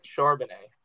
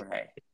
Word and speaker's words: Charbonnet: Zach Charbonnet. Fun fact. – Charbonnet.